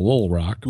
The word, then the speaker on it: Little Rock: And you'll notice that I pronounced it L-I-L, Little Rock. That's how it's pronounced.